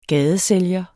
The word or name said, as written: gadesælger